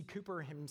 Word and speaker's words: Cowper: So William Cooper, it's spelled Cowper, but it's actually pronounced Cooper.